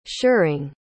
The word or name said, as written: shirring